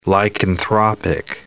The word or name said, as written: lycanthropic